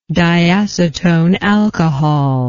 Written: diacetone alcohol

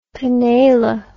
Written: panela

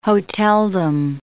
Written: hoteldom

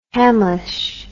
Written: Hamlisch

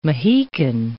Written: Mahican

